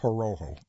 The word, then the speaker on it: Horoho: Patricia Horoho, that's H-O-R-O-H-O. I'm going to pronounce it Horoho.